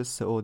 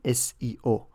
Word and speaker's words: SEO: از چیزی به اسم سئو نداریم اینطوری باید تلفظش کنیم S اول کلمه سرچ هست ای e اول کلمه انجین O اول کلمه اپتیمایزیشن یعنی SEO